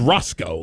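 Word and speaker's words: Racicot: It was Roscoe, like literally Roscoe. – Well, I'm getting this. It's funny because there's an A in it.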